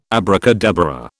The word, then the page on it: abracadabra: British English